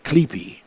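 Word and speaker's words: Asclepius: which is the rod of Asclef, which is actually pronounced as Clepe.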